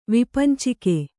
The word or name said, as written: ವಿಪಂಚಿಕೆ